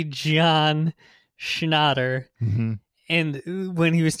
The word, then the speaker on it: Schnatter: Like, yeah, apparently he pronounces it Schnatter. – Yeah, not Schnatter. – Yeah, which is like, why would you want it to sound like snot? – Why would you want to sound like shat?